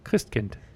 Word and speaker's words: Christkind: Say it like a German. – Christkind.